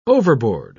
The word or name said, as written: overboard